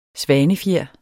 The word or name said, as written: svanefjer